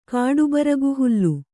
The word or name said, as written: ಕಾಡುಬರಗುಹುಲ್ಲು